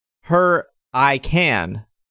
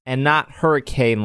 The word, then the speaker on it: Hurricane: The pronunciation of my hometown, Hurricane, is pronounced Hur-I-Can, not Hurricane like it's spelled.